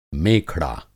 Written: मेखड़ा

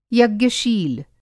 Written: यज्ञशील

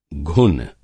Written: घुन

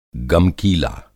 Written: गमकीला

